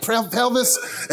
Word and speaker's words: pelvis: She's like, Elvis, like pelvis.